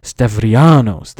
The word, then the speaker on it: Stavrianos: Leftin Stavarnios, I probably mispronounced his name horribly, Stavrianos, that's how you say it, Leftin Stavrianos, introduced the first real kind of course called world history.